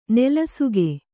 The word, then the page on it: ನೆಲಸುಗೆ: nelasuge